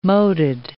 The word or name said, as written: moated